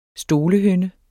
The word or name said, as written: stolehynde